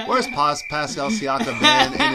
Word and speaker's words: Siakam: Siakam. – Pascal Siakam.